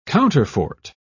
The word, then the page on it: counterfort: British English